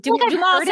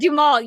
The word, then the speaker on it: Duhamel: Duhamel? Yes. Duhamel sounds right. I could not remember how to pronounce it. Yes. – This is the only time in the history of this podcast that I know how a last name is pronounced. – This, I'm glad. – This is my moment. – Duhamel, yes, that is correct.